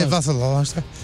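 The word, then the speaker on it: Vasilevskiy: Kadri's unlikely winner spoiled a brilliant performance from Tampa Bay's Andre Vasilevsky. Did I say that right? – Close. – Vasilevsky. – I would say Vasilevsky.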